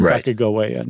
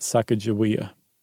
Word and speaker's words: Sacagawea: And not once do they spell that third syllable, ga, with a J sound, with a J. It's always a G. Okay. And they think that it was a hard G, so that it's Sacagawea, not Sacagawea.